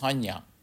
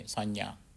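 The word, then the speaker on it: saññā: Vedana, V-E-D-A-N-A, vedana, that is feelings. And the third one is mirage, that is sanya, S-A, N with tilde A, long A, sanya, perception. And this one is sankhara.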